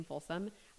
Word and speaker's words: Folsom: Jamie is spelt differently with two E's, and my last name, Folsom, always F as in Frank, O, L, S as in Sam, O, M as in Mary.